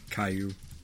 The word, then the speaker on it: Caillou: Caillou. – That's how you pronounce it. – Oh, my God. – Yeah, it's just spelled like Callaloo because I don't know. – Yeah. – Two L's make a Y.